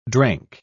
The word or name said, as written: drank